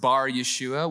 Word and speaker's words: Bar-Yeshua: There, they met a Jewish sorcerer and false prophet named Bar-Jesus, which means son of Jesus, or it would be pronounced Bar-Yeshua, which directly translates to son of salvation.